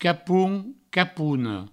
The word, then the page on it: capon: provençal; capona; capoun; capouno